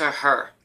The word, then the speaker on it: H.E.R.: I'm really into her. H-E-R as well. Yeah. Is that how you say it? Her? – Oh, H-E-R? Yeah.